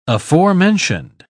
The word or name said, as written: aforementioned